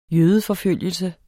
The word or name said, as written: jødeforfølgelse